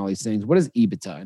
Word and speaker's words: EBITDA: He said EBITDA. – So wow, you said it right.